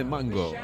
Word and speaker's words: Mungo: Yo siempre decía Mungo Jerry, pero hasta que llegó Kennedy me dijo, no se dice Mungo, se dice Mango. A mí me sonaba mal lo del mango, pero es así.